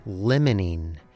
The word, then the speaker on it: limonene: Today on The Weekly Experiment, we're asking the question, what is limonene? The word is spelled L-I-M-O-N-E-N-E. Its spelling looks like, and pronunciation sounds like, a cross between the words lemon and lime.